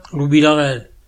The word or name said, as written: Villarel